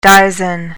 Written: dizen

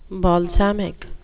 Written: balsamic

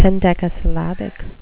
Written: hendecasyllabic

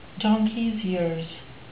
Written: donkeys years

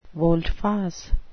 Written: volte-face

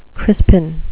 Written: crispin

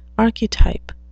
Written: archetype

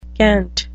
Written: cant

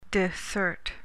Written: dissert

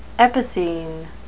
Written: epicene

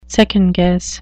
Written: second-guess

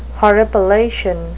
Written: horripilation

